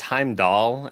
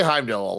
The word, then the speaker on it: Heimdall: I feel like they say Heimdall a lot, though. – They call Heimdall, but it is spelled with an A. – I think it's the accent.